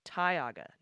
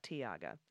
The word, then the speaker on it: Tioga: Tioga County, PA is pronounced Tioga, not Tioga.